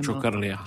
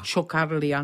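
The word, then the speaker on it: Ciocărlia: jak se to přesně vyslovuje? – Čokarlia. – Čokarlia, nádherný.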